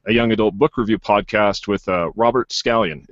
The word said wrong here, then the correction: Scanlon: Is it Scallion? Did I say that right? – Scanlon. That's okay. – Scanlon, sorry, Robert Scanlon, there we go.